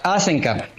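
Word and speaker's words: Hasenkamp: ¿Y dónde queda ¿Cómo es Haxensan? ¿Cómo es? – Asenkamp. – Asenkamp. – Asenkamp. Claro. Digamos, la pronunciación original sería de Asenkamp, porque sí. Es de origen alemán. Sí.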